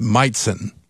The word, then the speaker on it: Majcen: Got an interesting spelling of his last name, M-A-J-C-E-N, and it's pronounced Meitzen.